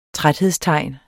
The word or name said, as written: træthedstegn